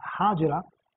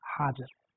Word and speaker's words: Hajar: It is also pronounced as Hajira, but actually the pronunciation which is found in books is Hajar.